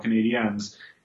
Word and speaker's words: Canadiens: the Montreal Canadiens, in the Atlantic.